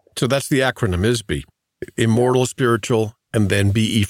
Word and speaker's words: ISBE: Let's begin with the all life forms are ISBEs. IS-BEs. Is that how you say it? – Yeah, IS. I think it's called or pronounced ISBE. – Right. – It's spelled IS-BE.